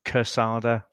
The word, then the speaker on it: Quesada: So it's pronounced Quesada. So Quesada. as if it's spelled with a K, but it is spelled with a Q, isn't it? So it looks like Quesada, but it is Quesada.